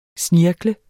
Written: snirklet

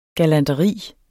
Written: galanteri

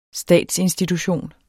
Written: statsinstitution

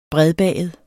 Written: bredbaget

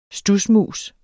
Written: studsmus

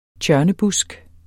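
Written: tjørnebusk

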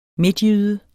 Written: midtjyde

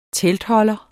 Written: teltholder

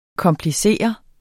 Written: KOMPLICERES

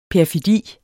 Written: perfidi

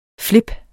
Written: flip

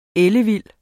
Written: ellevild

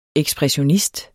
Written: ekspressionist

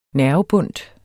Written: nervebundt

